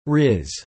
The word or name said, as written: rizz